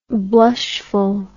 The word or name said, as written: blushfulness